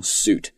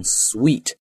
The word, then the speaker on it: suite: For example, this is my suit, this is my new suit, this is my suitcase, and the suit that brought to the court, 是吧，比如说审理案件的这个案件也叫 lawsuit, 都是这么读的，但是你注意在这个 suit 后面如果加了一个 e, 这个单词就不能读成 suit, 它应该读成 sweet。因为这个词呢它是从法语当中过来的，它的发音其实跟英语的糖果 sweet 是一模一样的，叫 sweet。